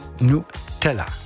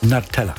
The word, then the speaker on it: Nutella: Nutella oder Nutella. Jemand, je nach Land, wie es ausgesprochen wird.